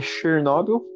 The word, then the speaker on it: Chernobyl: Eu assisti a série Chernobyl, da HBO. Aí eu, eu sei sobre os perigos da radiação. Eu queria só corrigir a sua pronúncia. É... Chernobyl.